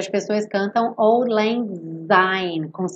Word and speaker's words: syne: Que essa palavra é pronunciada sign, old lang sign, esse S com som de S, não é com som de Z.